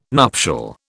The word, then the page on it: nuptial: British English